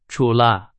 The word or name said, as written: 除了